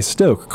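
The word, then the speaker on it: Stōk: This is stock? Is that how you would pronounce it? S-T... – They have a line over the O. – Oh, okay. Stoke cold brew iced coffee.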